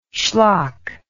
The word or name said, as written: shlock